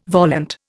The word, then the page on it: volant: Indian